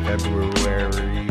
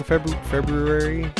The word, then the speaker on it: February: It's Double Dungeon for Wednesday, February 3rd. Do you pronounce the R in February? – February?